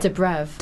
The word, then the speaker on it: Dobrev: Dobrev.